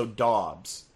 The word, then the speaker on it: Dobbs: Could you have I heard a beat writer from Nevada, where he played college football, say that she was corrected by him specifically that it's more of an AU sound. So Dobbs is the correct pronunciation, okay. Which is kind of depressing because getting Dubs with Dubs sounds good.